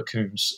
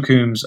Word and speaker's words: Coombes: On TikTok, it's do something for nothing, and on Instagram, it's at Joshua Coombs, which is that's the best places. And do something for nothing on Instagram too. But Joshua Coombs, my surname is spelled C-O-O-M-B-E-S.